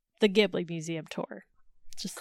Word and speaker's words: Ghibli: She had been doing it a long time, and she said it's Ghibli with a J. – Ghibli. – She even said, I know you Americans pronounce it Ghibli, but in a Japan, Ghibli. We say Ghibli.